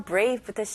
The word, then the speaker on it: brave: B 발음도 있고 V 발음도 있습니다. 이거를 확실하게 연습할 수 있어요. 네, 일단 B 발음은 입술이 붙어야죠. 그래서 B, B. 그 다음에 R 발음. R에는 힘을 주셔야 돼요. Brave. 네, V 발음으로 마무리하는데 입술이 붙으면 안 되죠. Brave. 이렇게 Brave. 윗니, 밑에 입술 다 해야죠.